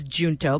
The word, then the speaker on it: Junto: And so, and then the word Junto, it's spelled J-U-N-T-O. So people often say it's Junto, but it's a Spanish word.